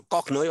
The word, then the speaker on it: Korchnoi: Korchnoi. Bueno, es que la CH ahí se pronuncia como J. Bueno, Korchnoi, vamos a decirlo.